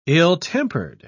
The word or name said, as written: ill-tempered